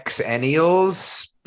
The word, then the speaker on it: Xennials: Whatever people tell me to pronounce how they I would say Xennials. Because it's supposed to be Generation X and millennials.